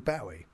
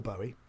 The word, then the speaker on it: Bowie: And a lot of your show was about David Bowie. – Yes. – Or Bowie. depending on your pronunciation or pronunciation.